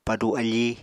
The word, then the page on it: padu afí: Apatani